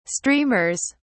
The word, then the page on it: streamers: British English